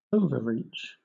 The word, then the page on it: overreach: British English